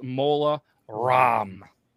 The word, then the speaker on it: Mola Ram: Yep, this round is Voldemort versus Mola Ram. Like CD-ROM, but apparently it's spelled Ram.